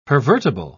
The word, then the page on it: pervertible: British English